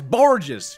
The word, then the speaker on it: Borges: Come on, it's Borges. Say it right.